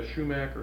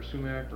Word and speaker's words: sumac: We'd maybe say a shumac or sumac or...